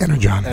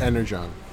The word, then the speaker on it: Energon: Energon. – Energon. – It's got a hard G.